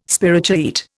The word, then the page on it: spirochaete: Indian